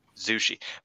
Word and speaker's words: Zushi: But so apparently you're supposed to pronounce it Azumi Sushi, like with a Z, like a zoo, you know, like Zushi.